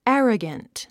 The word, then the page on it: arrogant: ǽrəɡənt; アラガント